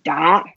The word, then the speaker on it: 打: Da. – Da, yeah, da is the third tone. So we go down first and go up and we sound a bit angry, upset. Da.